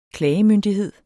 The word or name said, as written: klagemyndighed